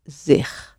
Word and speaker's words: zich: Zich is spelled Z-I-C-H. And that C-H is another way of spelling the G sound. So the G of zich is exactly the same sound as the G in goed or in graag.